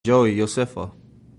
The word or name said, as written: Iosefa